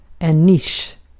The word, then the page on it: niche: English